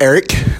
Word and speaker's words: erik: It's called Erik, not with a C, E-R-I-K, because in Turkish, what we would think of as a C actually has a J pronunciation.